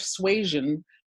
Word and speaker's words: suasion: And the interesting thing when you said, I'd never heard the term uplift suasion. Suasion. Am I saying that correctly? – Yeah.